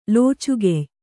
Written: ಲೋಚುಗೆಯ್

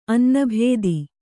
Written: ಅನನುಭೂತ

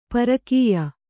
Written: ಪರಕೀಯೆ

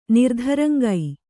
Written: ನಿರ್ಧರಂಗೈ

